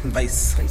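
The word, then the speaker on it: Weisse: Is it pronounced Weiss? – Weiss.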